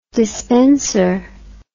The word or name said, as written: despenser